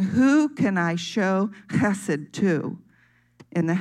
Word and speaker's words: chesed: It is a Hebrew word, and it's pronounced chesed.